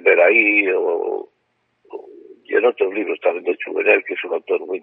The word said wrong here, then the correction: Jouvenel: Jovenel, J-O-U-V-E-N-E-L. Jovenel Bertrand, Bertrand, Bertrand, la palabra francesa Bertrand la conoce, ¿no? Sí, sí, sí. Bertrand de Jouvenel.